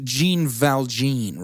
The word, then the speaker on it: Jean Valjean: So the name is pronounced Jean Valjean, right? – Yes.